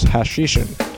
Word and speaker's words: Hashishin: Because this potion was made from hashish, the army became known as the Hashish Eaters, which in Arabic is Hashishin, or as the crusaders pronounced it, assassin.